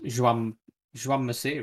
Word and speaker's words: Jaume Masia: It's just Joan Masia, isn't it? – Joan Masia, yeah.